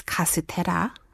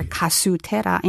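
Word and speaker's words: castera (カステラ): That's why it's originally castella, but it's castera. – Right. – Or kasutera in Japanese.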